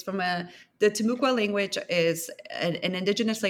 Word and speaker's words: Timucua: Um, so in the first few paragraphs of the book, you introduce the story of uh, I'm sorry, Timucua Eve. Am I pronouncing that correctly? – Timucua, Timucua, yeah.